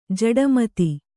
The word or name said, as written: ಜಡಮತಿ